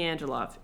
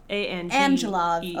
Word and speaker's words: Angelov: She meets Jimmy Angelo. Is that his name? – Angelo, I think. – Angelo? – It's like not Angelo. She pronounces it Angelo. It's like Angelo. – Okay. Jimmy. – He is from like Romania. – He's from Bulgaria. Jimmy Angelov. Yes. Jimmy Angelov. – Angelov. Okay. – Angelov. Yeah.